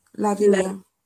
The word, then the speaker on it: Lavinia: Is Lavinia.